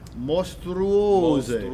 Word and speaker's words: mostruose: Mostruose. – Without singing it, it gets a little mushed. Mostruose. See now. – The stress is on O. Mostruose.